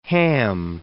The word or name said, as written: West Ham